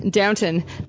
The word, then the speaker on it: Downton: How did I say it? How do you say it? – Downton. Downton Abbey.